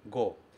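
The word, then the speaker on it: go (五): Aqui o kanji se pronuncia go.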